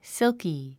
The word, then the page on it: silky: sílki; シルキィ